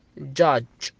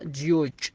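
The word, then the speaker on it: judge: وفي حالات شاذة إن إذا أتى انتهت الكلمة بحرف الإي وفيها حرف يو، سواء أتى الحرف الثاني أو الثالث أو الرابع بحرف يو، مثل كلمة عندنا كلمة جاج، جاج، ما تنطقها جيوج، تنطق جاج.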